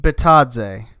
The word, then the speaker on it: Bitadze: Bitadze. – Bitadze at 18.